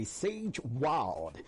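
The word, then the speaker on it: Xage Wild: Okay, the title of the song says Crude Medley by Sage Wild. Sage Wild is spelled X-A-G-E, then W-I-L-D. And the title of the song says Crude Medley.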